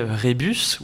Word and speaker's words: rébus: C'est-à-dire que pour faire par exemple deviner rébus ou rébu dans la prononciation communément acceptée,